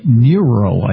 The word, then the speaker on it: Niro: and also a very interesting hybrid called the Niro. I believe the correct pronunciation is that, N-I-R-O.